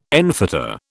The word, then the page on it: enfetter: British English